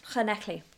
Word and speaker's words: Llanelli: Llanelli.